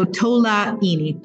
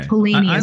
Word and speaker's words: Tolaini: So Tolaini is the last name. But oh, okay. – Tolaini is fine.